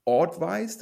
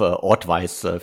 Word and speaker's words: Audvice: Auf jeden Fall der nächste Deal von Cast Capital hört auf den Namen Ortwise. Das ist irgendwie, glaube ich, die Mischung aus Audio und Advice, nehme ich an.